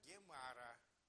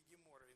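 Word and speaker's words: Gemara: Вот это комментариев, огромное количество комментариев, называется Гемара. Завершение, вот так буквально. Дополнение. Это уже написано на арамейском языке. Значит, в V веке записана палестинская гемара, или гемора иногда произносится.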